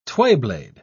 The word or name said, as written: twayblade